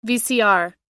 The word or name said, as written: vcr